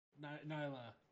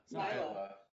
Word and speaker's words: Nyla: I don't think that's how you say her name. – Nyla. – Nyla. – That. Words. – Man, I totally transposed in my head the I and the L.